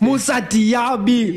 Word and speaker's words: Moussa Diaby: Moussa Diaby.